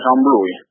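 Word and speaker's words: Gembloux: Vous êtes à Jumblou, en Belgique, je pense que j'ai bien prononcé Jumblou en Belgique. Bonjour. – Bonjour, je suis à Jumblou.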